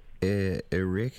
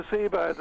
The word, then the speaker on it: Arecibo: What's going on in, how do I say this, Arecibo. – Thank you very much. Carry on. – Arecibo, the radio telescope.